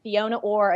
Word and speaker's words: Fiona Orr: I-O-N-A at, and then Fiona Orr again.